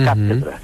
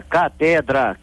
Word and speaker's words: Cátedra: Cátedra. E claro, né, como o carioca não é muito bom de pronúncia, o, o grito de guerra dele era catedra, catedra, catedra.